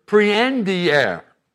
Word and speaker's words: prehendere: Now, our English word comprehend or apprehend both stem from the Latin word prehendere. That's the way you pronounce it in the Latin language, which means to grasp.